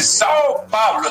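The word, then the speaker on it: São Paulo: Dice Sao Paulo, ¿está bien dicho así? – Pues perfecto.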